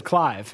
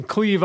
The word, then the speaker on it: Clive: My license said Clive. And he's like, what's happened in Cleve, Iowa? And we all start cracking up because he pronounced it wrong.